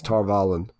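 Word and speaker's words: Tar Valon: The accent's on the first syllable, Valon. – Okay. – Tar-Valon. Oh, you're right, Jenna. It's Valon, technically.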